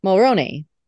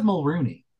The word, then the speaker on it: Mulroney: Is it Mulroney or Mulrooney? – Mulroney. – I always said Mulrooney. – Well, you always said it wrong then.